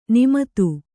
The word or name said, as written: ನೇಮಿತ